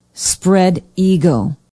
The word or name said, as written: spread eagle